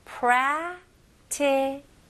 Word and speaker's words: practical: 여기에서는 이제 prac라고 할 때 첫 번째 그 C 발음을 그렇게 강하게 안 하셔도 됩니다. 바로 살짝만 크 소리 내면서 T로 넘어가시면 훨씬 더 자연스러워요. 그래서 practical 보다는 practical. practical. 오케이? 강세도 앞부분에 들어갑니다. 해볼게요.